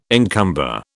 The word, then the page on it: encumber: British English